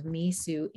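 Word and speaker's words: Mesu: It's M-E-S-U, just like it sounds, Misu Andrews.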